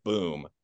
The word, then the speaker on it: Boum: But in the NCAA tournament, there was this player on Xavier named Sule Boom, B-O-U-M, but he pronounces it Boom.